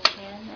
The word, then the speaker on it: Chan: My name is Christine Chan, and the last name is spelled C-H-A-N.